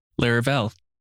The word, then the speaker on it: Laravel: Laravel.